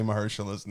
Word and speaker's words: Mahershala: I put the emphasis on the wrong syllable every time with Mahershala.